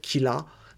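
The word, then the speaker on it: Kira: Donc, c'est en fait euh, le R en japonais se prononce comme un L et ça se dit en fait Killa et ça fait penser donc à, Killer. Euh,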